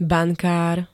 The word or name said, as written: bankár